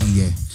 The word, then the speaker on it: Ige: Is oh, that Ige? how you say his name? I think it's Iggy.